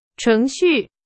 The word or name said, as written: 程序